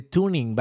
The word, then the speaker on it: TuneIn: Si tú tienes, por ejemplo, en tu teléfono quieres descargar una aplicación, puedes descargar la aplicación de Tuning, ¿va? Se escribe como TuneIn, así, tal cual.